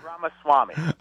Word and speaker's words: Ramaswamy: Can you pronounce it for me so I have a correct pronunciation? – Vivek Ramaswamy.